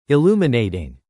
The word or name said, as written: Illuminating